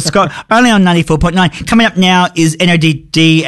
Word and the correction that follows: NODD: and Maya Wright with AMPM. God, I can read. – It's pronounced Noted.